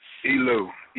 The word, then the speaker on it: Elu: This your boy, Elu. I'm checking in with the Cherry Heartache. – Hey, what's up? What'd you say your name was? – Elu, E-L-U.